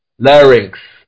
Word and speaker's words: larynx: How do you say it? – Larynx. – Larynx. Okay. Larynx is the upper part of the trachea.